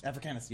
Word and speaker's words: Africanus: You know, man is a continuum, because you go all the way back to Neanderthal, Homo erectus, African, how do you say that? – Africanus, yes.